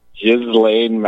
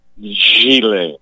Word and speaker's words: Ghislaine: I hear people say Ghislaine Maxwell. – Yeah. – It's a French name, and it's pronounced Ghislaine.